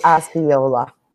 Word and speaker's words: Osceola: He was brought from the jail to Osceola? How do you say it? – It would be Osceola.